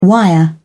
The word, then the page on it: wire: British English